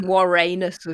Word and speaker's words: Vorenus: I called him Warrenus as well, because that's the Latin pronunciation. And then I compromised and called him Vorenus when I was reading out.